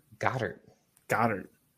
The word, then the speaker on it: Goedert: Why Goedert? Why can't you pronounce it correctly? – What is it? Goedert? What is it? – Goddard.